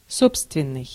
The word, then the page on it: propre: France; /pʁɔpʁ/